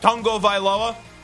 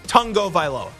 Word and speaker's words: Tagovailoa: It's not Tagovailoa. I've interviewed him before. I've asked him how to pronounce his name. I've heard him say his name before. It's Tua Tagovailoa.